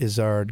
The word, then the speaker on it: Izzard: And I pronounce it Izzard because I believe that is the correct pronunciation.